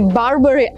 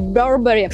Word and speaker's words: Burberry: этот бренд правильно произносится не Барбари, а Burberry.